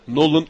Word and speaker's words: Nolan: Nolan yazılır. Nolan okunur.